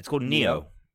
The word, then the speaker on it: Nioh: Oh, it's called Neo. Yep. It's spelled N I O H.